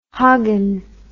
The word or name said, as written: hoggin